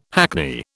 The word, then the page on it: hackney: British English